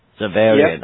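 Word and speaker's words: Xaverian: And you also have, I'll probably mispronounce it, but you also have musicians from, Zavarian? – Zavarian.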